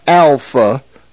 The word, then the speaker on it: alpha: It is pronounced A as in father. The first Greek letter is Alpha. Its pronunciation or phonetic sound is A, as the A is in the word father, A, Alpha.